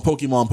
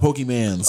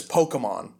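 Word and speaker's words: Pokémon: I'm like that one parent who keeps calling Pokemon Pokemans. – Oh, stop. – Yeah. – Dude, that was my parents. – That's hurtful. – It was cringe, dude. It was cringe. I was like, say it right.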